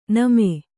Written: ನಮೆ

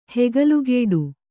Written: ಹೆಗಲುಗೇಡು

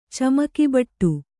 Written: ಚಮಕಿಬಟ್ಟು

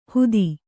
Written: ಹೂತಿ

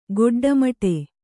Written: ಗೊಡ್ಡಮಟೆ

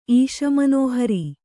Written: ಈಶಮನೋಹರಿ

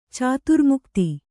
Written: ಚಾತುರ್ಮುಕ್ತಿ

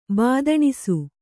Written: ಬಾದಣಿಸು

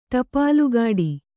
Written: ಟಪ್ಪಾಲುಕಟ್ಟೆ

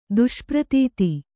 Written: ದುಷ್ಪ್ರತೀತಿ

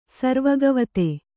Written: ಸರ್ವಗವತೆ

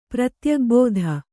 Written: ಪ್ರತ್ಯಗ್ಭೂಧ

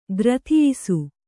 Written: ಗ್ರಥಿಯಿಸು

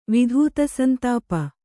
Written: ವಿಧೂತಸಂತಾಪ

